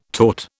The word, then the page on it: taught: British English